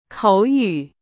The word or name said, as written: 口语